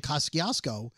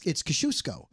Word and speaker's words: Kosciuszko: So I emailed them and I said, you guys are saying Kosciuszko, but it's Kosciuszko.